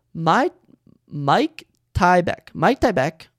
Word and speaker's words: Myktybek: M-Y-K-T-Y-B-E-K. So Mike Tybek. Orolbai. O-R-O-L-B-A-I.